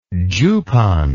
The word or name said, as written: jupon